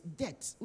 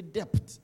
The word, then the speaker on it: debt: Now, that word, it's debt. We don't say depth, depth. You keep on saying depth. It's debt. Yeah? D-E-B-T. The B is silent.